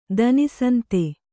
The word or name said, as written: ದನಿಸಂತೆ